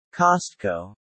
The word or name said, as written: Costco